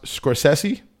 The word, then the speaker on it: Scorsese: First of all, you know his name is actually pronounced Scorsese.